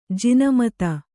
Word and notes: ಜಿನಮತ: jina mata

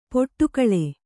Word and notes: ಪಡುಕುಳಿ: kannada

